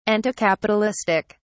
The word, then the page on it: anticapitalistic: British English